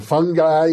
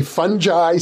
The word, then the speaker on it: fungi: One of the interesting facts I gathered from your book is that some people say fungi, some people say fungi. How do you pronounce it? – I'm middle of the road. I'm fungi.